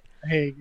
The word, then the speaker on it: Hague: And he conducted most of his studies from his lab in, is it The Hague? I think that's how you pronounce it. – Hague, yeah.